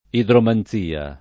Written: idromanzia

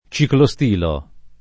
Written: ciclostile

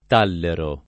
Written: tallero

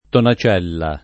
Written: tonacella

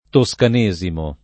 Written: toscanesimo

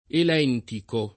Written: elentico

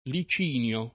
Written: Licinio

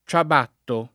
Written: ciabattare

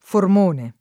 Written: Formone

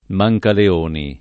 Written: Mancaleoni

